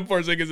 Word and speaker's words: Porzingis: The emphasis on the end of Porzingis is perfect.